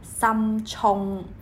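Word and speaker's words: 深涌: 深冲呢，在广东话的发音是三冲。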